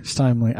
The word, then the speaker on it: Steimle: Stimley.